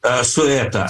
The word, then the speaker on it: Ceuta: It's a Belgium, Cyprus, but we come to, how do you say that, Ceuta, Spain? – Sueta, S-U-E-T-A.